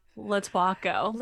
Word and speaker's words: Lotuaco: Lotuaco.